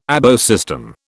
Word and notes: ABO system: British English

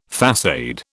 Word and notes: façade: British English

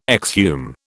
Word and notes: exhume: British English

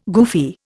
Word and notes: goofy: Indian

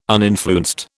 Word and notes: uninfluenced: British English

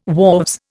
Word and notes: wharves: Indian

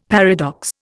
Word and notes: paradox: Indian

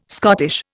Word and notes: Scottish: Indian

